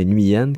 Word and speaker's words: Nguyen: J'ai appris à prononcer son nom, ce n'est pas Nguyen, mais bien Nguyen que qu'on dit.